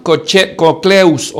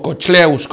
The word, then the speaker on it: Cochlaeus: Luego tenemos a Juan Cocleus, o Cocleus, como quieras pronunciarlo.